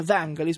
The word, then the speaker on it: Vangelis: Invece Vangelis, bisognerebbe pronunciarlo così, come? – Il nome Vangelis, perché non male. Egli è greco,